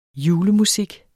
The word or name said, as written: JULEMUSIK